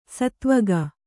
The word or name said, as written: ಸೇದೆವುಗು